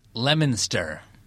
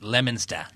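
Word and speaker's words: Leominster: That was a curveball because it's spelled L-E-O-M-I-N. – Oh. – But sounds like lemon. Yeah. But yeah. I lived up there in Boston area for a while. I got family up there. So I know that it's pronounced Lemonster.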